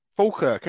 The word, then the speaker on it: Falkirk: We have Ross Jardine up in Falkirk, ain't you? – Yep, that was great pronunciation.